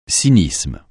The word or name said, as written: cynisme